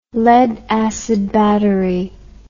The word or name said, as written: lead acid battery